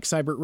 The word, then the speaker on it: Seibert: Again, that is at Mike Seibert Radio. And the spelling is S-E-I-B-E-R-T. So it's either spelled backwards or pronounced backwards, depending upon where you're from.